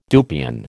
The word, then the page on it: dupion: American English